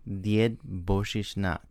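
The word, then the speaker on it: Djed Božićnjak: Died Božićnjak. – Died Božićnjak. – Died Božićnjak. – So the accent's on the last syllable on this one, right?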